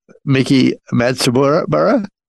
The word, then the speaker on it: Miki Matsubara: We're going to come in with Wash, which was also Miki Matsubara. Did I say it right? – Okay. Yeah, you said it right.